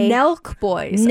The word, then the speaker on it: Nelk Boys: And Nelk Boys? – Nelk Boys. I'll spell it for you. N-E-L-K. – Nelk Boys.